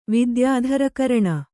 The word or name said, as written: ವಿದ್ಯಾಧರಕರಣ